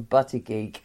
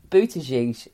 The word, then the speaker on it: Buttigieg: I did actually just watch him being interviewed by Rachel Maddow just so I could get the pronunciation, because I have been calling him Butty Geek. I didn't think that was right. Buttigieg is apparently what it is.